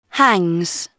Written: hangs

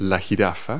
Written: giraffe

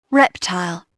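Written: reptile

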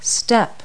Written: step

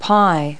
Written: pie

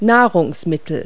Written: food

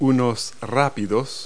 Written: rapids

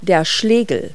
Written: mallet

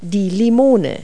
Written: lime

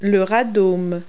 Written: radome

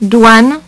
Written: douane

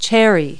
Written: cherry

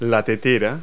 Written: teapot

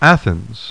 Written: ATHENS

